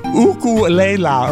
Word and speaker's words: ukulele: I love this story because not only is it so Hawaiian, the guy uses the Hawaiian pronunciation of ukulele. Ukulele, or whatever that is they say.